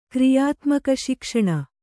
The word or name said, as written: ಕ್ರಿಯಾತ್ಮಕಶಿಕ್ಷಣ